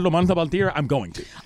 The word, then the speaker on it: Montalban: The Montalban. – You have to say it like that. – Ricardo Montalban. – Ricardo Montalban.